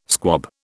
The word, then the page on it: squab: British English